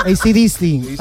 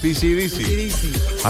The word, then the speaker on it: AC/DC: Hombre, no se olviden nunca de la pronunciación de AC/DC. – Digo, AC/DC, ha dicho ella. – Que no es DC/DC.